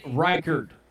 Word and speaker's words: Reichard: They don't get in the field goal range, I don't think, One thing I'm sick of is you saying Willie Riker's name wrong week in and week out. You're obviously not preparing properly. Are you anxious? Willie Reichard.